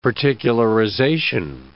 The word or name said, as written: particularization